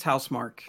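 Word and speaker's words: Housemarque: So um, my next game that I had on my list was Returnal, which is from is it pronounced House Marquee or House Mark? I think it's House Mark. Okay, I always mm-hmm. call it Marquee, uh, just because of the way it's spelt. But I've heard it pronounced as House Mark.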